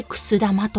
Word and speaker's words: 久寿玉: くす玉というお酒を醸しているんですが、このくす玉、漢字で書きますと、久しい、永久の久に、えー、ことぶきに、そして玉と書いてくす玉と読むんですよね。